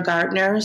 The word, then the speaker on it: Gardner: Alexandra Gardner. So my last name is spelled G-A-R-D-N-E-R.